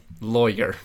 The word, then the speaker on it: Laurer: L-A-U-R-E-R. Laurer. – Lawyer. – Lawyer. That's it.